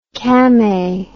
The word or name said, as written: camay